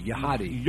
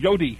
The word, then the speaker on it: YHOTY: And Y-H-O-T-Y, Yahadi. – Yodi. – Oh, Yodi. – Yodi. – Oh, should have known the H isn't pronounced.